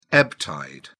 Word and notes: ebb tide: British English

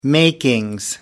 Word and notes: makings: British English

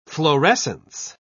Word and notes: florescence: British English

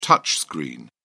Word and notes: touch screen: British English

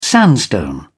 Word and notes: sandstone: British English

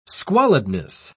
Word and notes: squalidness: British English

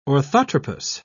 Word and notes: orthotropous: British English

